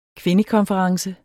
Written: kvindekonference

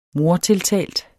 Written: mordtiltalt

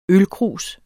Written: ølkrus

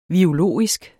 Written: virologisk

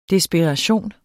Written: desperation

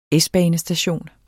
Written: S-banestation

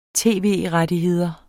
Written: tv-rettigheder

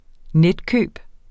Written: netkøb

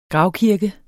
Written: gravkirke

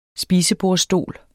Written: spisebordsstol

